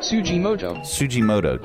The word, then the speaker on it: Tsujimoto: how do you pronounce his last name? – Tsujimoto. – Taro Tsujimoto.